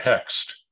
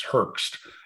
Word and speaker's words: Hoechst: The company was called, you and I would call it Hext. I think it's Hoechst, something like that. – Okay. – The German pronunciation I learned is Herxt.